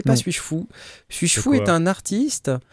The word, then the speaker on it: S8JFOU: Alors, ça s'écrit S8JFOU, mais ça se prononce Suis-je fou Et c'est intitulé L'autosuffisance par le software.